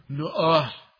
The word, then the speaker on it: Noah: And so he says, I'm going to name my son Noah. That's how it's said in the Hebrew. Noah.